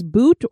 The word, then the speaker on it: bhoot: The Indian people have a particular term they use for a ghost, and that is bhoot or bhuta, and that's spelled B-H-O-O-T or B-H-U-T-A.